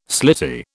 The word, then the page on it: slitty: British English